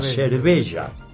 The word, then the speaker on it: cerveja: Por ejemplo, en Brasil se escribe cerveja. Pero esa J se pronuncia Y. Se pronuncia cerveja.